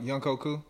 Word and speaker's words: Younghoe Koo: I still see like the Atlanta kicker uh, Young Hoo Uh, not sure if I pronounced. – No, he was taken. Young Younghoe Koo.